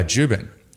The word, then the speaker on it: Jubin: Jubin. Yeah. Jubin, like Reuben with a J.